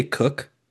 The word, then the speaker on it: Coke: By the way, before I move on any further, so I say Edward Coke. Did you say Cook? – Yes. – is that the right way to say it? – Yes.